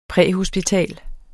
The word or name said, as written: præhospital